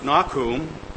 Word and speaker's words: Nahum: Nahum. In Hebrew, it is pronounced Nakum, and it has a particular interest once you see about the name.